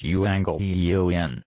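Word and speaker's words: euangelion (εὐαγγέλιον): Evangelion, pronounced euangelion, is a compound of two common Greek words.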